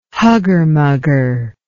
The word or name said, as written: hugger mugger